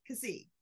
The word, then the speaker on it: Keesee: Keesee? – That's Keesee. – Keesee.